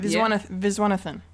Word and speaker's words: Viswanathan: Yeah, you pronounced it right. Viswanathan.